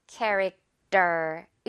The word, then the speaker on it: character: 자, 이럴 때 character 이렇게 T를 너무나 명확하게 T처럼 발음하기보다는요. character 이렇게 마치 떠처럼 발음이 되면요.